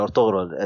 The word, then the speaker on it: Ertuğrul: In Arabic they say Ertuğrul. In Turkish I think they pronounce it Ertuğrul. – Yeah, Ertuğrul.